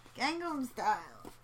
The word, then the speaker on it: Gangnam: As long as it's not Gundam style. Did I say that right? – Probably. No. – Whatever. – Yeah. Gangnam. – Gangnam style.